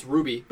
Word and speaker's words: RWBY: So they do like you got like for instance the main one's Ruby, but it's spelled RWBY.